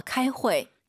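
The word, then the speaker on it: 开会: 카이 후이. 네. – 카이 회. – UI 사이에 에 넣으세요. 회. 자, 8시 15분에 회의한다. 들어볼게요. – 8点1刻 카이 회.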